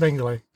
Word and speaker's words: Mengele: Oh, it's Mengele? I thought it was Mengele. But anyway, yeah. – It's pronounced Mengele.